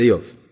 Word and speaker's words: איוב: זה איוב. למה, שלא? לא כן, ככה מבטאים את זה.